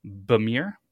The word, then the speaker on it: Bemer: And then there was Bob Bemer. How would you say that? B-E-M-E-R.